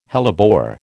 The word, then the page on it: hellebore: American English